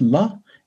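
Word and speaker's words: la (བླ): Baladala or Wiley BLA, bla, pronounced la, is regularly spelt Raladala, so rla in old Tibetan.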